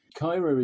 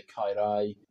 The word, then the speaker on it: Caerau: If you were in a different part of Wales, you would probably pronounce that word quite differently. You might say Cairai, but we're in the sort of the southwest suburbs of Cardiff, and there's a very particular Cardiff accent.